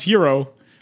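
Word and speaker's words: Hiro: I know it's not spelt like that, but it's pronounced Hero.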